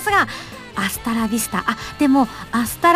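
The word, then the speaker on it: hasta la vista: スペイン語は H は発音しないんですよねなので HASTALAVISTA でございますそれでアスタラビスタと読みます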